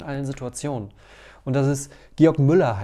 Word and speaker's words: Georg Müller: Manchmal auch George Müller, also weil der, es ist ein Deutscher, der aber dann nach England gegangen ist. Und mal wird er so, mal so ausgesprochen, aber eigentlich Georg Müller.